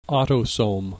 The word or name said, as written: autosome